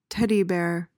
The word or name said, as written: teddy bear